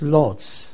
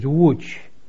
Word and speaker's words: Łódź: You pronounce it Lodz. We pronounce it Łódź. It's spelled L-O-D-Z, and it's a very large industrial place.